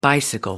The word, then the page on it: bicycle: American English